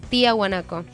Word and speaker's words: Tiahuanaco: de Tiahuanaco. Lo dije bien, ¿no? – Sí. Lo dijiste bien, es un libro espectacular de Andrés Rivera, yo lo he leído, así que me imagino que la obra debe estar buena también.